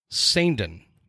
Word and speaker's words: Saindon: Gabriel Saindon.